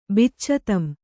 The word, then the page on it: ಬಿಚ್ಚತಂ: biccatam